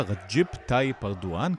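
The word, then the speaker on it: Recep Tayyip Erdoğan: כבר 20 שנה שולט בטורקיה רג'יפ טאי פרדואן, ככה צריך לבטא את השם שלו, על האיש הזה נדבר בדקות הקרובות לקראת הבחירות בטורקיה, האם הוא ימשיך לכהונה נוספת, וגם אם הוא לא ייבחר, אולי עדיין ימשיך.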